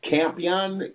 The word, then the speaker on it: Campion: Rick Champion, Campion, C-A-M-P-I-O-N, however you like to pronounce that.